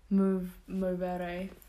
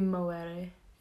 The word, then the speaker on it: movere: To be fair, if we were going to go for the Latin pronunciation, it would be moere instead of movere, like with a V. Because, say for example, you wanted to say man in Latin, it would be vir instead of vir, but it's spelled with a V, but they pronounce it with a W.